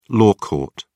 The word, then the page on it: law court: British English